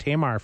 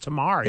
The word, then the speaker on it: Tamar: I had Tamar from the time I was little. – Yeah, me too. – But it's Tamar.